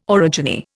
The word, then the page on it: orogeny: Indian